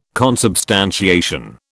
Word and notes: consubstantiation: British English

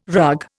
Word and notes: rug: Indian

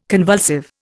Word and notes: convulsive: Indian

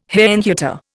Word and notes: Herrnhuter: Indian

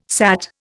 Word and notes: sat: Indian

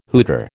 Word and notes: hooter: American English

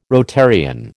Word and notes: Rotarian: American English

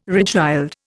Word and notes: regild: Indian

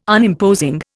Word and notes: Unimposing: Indian

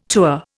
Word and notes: tour: Indian

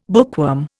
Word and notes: bookworm: Indian

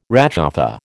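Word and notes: réchauffé: American English

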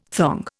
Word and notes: thong: Indian

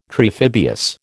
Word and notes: triphibious: American English

